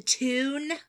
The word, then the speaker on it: Toon: The Toon? – The Toon. – Spell it. – T-O-O-N. – So Toon, like cartoon. – Like cartoon. The Toon.